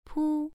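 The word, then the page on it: pu: tone 1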